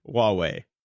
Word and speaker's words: Huawei: Yahweh? Huawei? Huawei? What's the, how do you pronounce that? – Huawei.